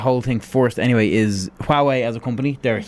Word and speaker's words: Huawei: But anybody who has a Huawei phone, I always say Huawei. – Is that I've heard so many different pronunciations of it. – Hawaii. – I think it's Huawei.